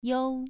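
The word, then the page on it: you: tone 2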